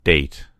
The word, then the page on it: date: American English